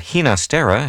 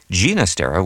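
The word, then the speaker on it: Ginastera: I probably should mention that Hina Stera has become the pronunciation you hear most often, but Gina Stera with a hard G is probably closer to what Alberto heard at home.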